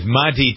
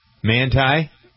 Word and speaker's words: Manti: So Katie Couric is going to have the first interview with Manti Te'o. – Wait, who? – Yeah. – Who? – Is that how his name is pronounced? – Manti. – Mm-hmm. Manti.